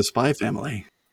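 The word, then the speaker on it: Spy × Family: I'm not familiar with the Spy Family. – If you spell it out, it's Spy X Family, but you don't pronounce the X, and it's a manga anime.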